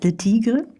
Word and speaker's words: Le Tigre: Spricht man die so aus? Le Tigre? – Ja.